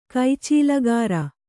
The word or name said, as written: ಕೈಚೀಲಗಾರ